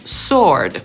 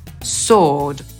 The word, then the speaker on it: sword: Myślę, że teraz już możemy być pewni, że po amerykańsku miecz wymawiamy jako sword, czyli pomijamy literę W. Brytyjczyk pominie również literę R, czyli z tych wszystkich pięciu liter, które to słowo zawiera, wymówi tylko trzy i powie sword.